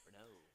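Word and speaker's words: Reneau: Renew? Is that how you say it? – Renew.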